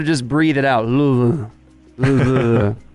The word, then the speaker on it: Louisville: You actually pronounced Louisville the proper way. People in Kentucky are supposed to do it. Everyone else across country says Louisville.